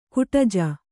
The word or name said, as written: ಗೂಢಜ